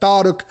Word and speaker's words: Taruk: Like sounds like Shahrukh, rhymes with Shahrukh, but Taruk.